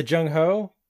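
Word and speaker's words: Zheng He: Zheng-He? – Yeah, the Zheng-Ho. It's pronounced okay. Zheng-Ho. He was a Chinese explorer.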